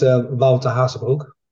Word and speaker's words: Wouter Hazebroek: Did I pronounce that okay? – Mostly. – Mostly. – It's Wouter Hazebroek. – Okay. – It's quite hard to say it in a language.